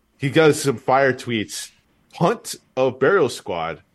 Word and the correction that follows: Bunt: I think it's his punt. I always get stuck on this. It is. It's bunt. It's a B.